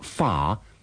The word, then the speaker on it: far: Remember that the final R of far isn't pronounced unless it's followed by a word that begins with a vowel.